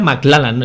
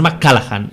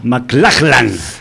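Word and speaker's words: MacLachlan: Eh, es que se pronuncia McLallan, eh, no es McCallaghan.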